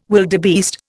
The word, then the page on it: wildebeest: Indian